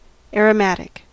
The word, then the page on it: aromatic: American English